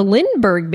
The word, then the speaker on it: Lindbergh: The Lindbergh baby.